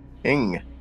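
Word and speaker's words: Ng: It's N-G. – Ing. – Ing? – Ing, yeah. – Ing Lee? – Sounds like only something. – No, well, I had a teacher with a name N-G, and his name was Mr. Ing.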